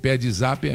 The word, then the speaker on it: Pedzap: Pedzap é P-E-D, o D é mudo, zap, Z-A-P.